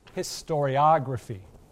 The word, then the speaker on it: historiography: It's pronounced historiography.